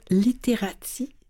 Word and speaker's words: Literati: lecture Literati. C'est comme ça qu'on prononce, aux États-Unis. Et vous collaborez avec La Clé, où vous animez des heures du conte improvisées sur Facebook.